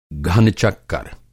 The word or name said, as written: घन-चक्कर